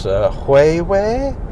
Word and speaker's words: Huawei: It's Huawei, I think it's pronounced. Huawei, some people call it.